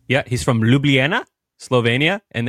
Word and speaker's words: Ljubljana: It's spelled like this: Ljubljana. Ljubljana.